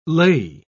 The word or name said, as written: lei